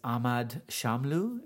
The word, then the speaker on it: Ahmad Shamlou: And this is Ahmad Shamlu.